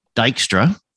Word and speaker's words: Dijkstra: but I'm speaking with Paula Dijkstra from the Netherlands, correct? – Yeah, that's totally correct.